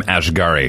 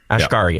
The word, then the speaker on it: Asghari: So how do you say his last name? Brittany's husband. – Sam Asghari. – Asghari? Asghari. Yeah. Okay.